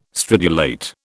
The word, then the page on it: stridulate: British English